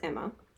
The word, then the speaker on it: Emma: So, my name is Emma.